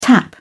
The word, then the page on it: tap: British English